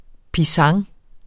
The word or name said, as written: pisang